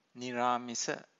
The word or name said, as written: niramisa